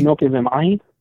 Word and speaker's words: Inoke Vamahi: Inoke, what? How did you say his first name? – Yeah, Inoke. – Yeah. Inoke, okay. So there's no vowel at the end, but that's interesting.